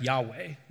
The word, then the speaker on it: Yahweh: It would be pronounced something like Yahweh, which is a word you've probably heard.